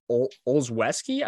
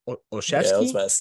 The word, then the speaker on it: Olszewski: Uh, he hit Gunnar Olszewski, I believe is how you pronounce his name. – Olszewski? Yeah, Olszewski. Yeah.